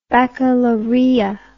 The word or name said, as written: baccalaureat